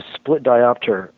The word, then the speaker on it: split diopter: I agree with you, because the split diopter, am I saying that right? That's what the term is for what he does in Blowout, correct? – Yeah. Yep.